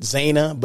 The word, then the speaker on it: Zana: So Zana Usher, Zana, Z-A-N-A. You know, I always pronounce it Zana, but no, it's Zana.